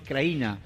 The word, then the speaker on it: Krajina: La zona ocupada de los rebeldes, los serbios crearon una autoproclamada República Serbia de Krajina, o Krajina, porque en realidad la J se pronuncia como una I. Krajina.